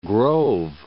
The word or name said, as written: Grove City